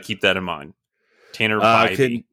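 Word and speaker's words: Bibee: Sunday, 23-year-old right-handed pitcher Tanner Beebe continues to deal. So hang on real quick. I got information that it's pronounced Bybee, and I would have Bybee? Never known that. Yep, it's Bybee.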